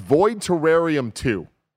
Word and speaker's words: Void Terrarium: The game is pronounced Void Terrarium 2.